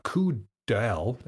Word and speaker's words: coup d'oeil: coup d'oeil. It's spelled C-O-U-P D apostrophe O-E-I-L.